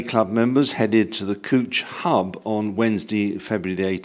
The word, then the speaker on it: Cwtch: And perhaps I should say that Cooch is spelt C-W-T-C-H, so I'm assuming the W is a Welsh W.